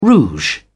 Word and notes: rouge: British English

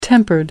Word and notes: tempered: American English

